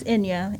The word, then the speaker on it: Enja: As Will said, my name is Enya, and if you ever see my name spelled, it is spelled E-N-J-A, but the J carries a Y sound.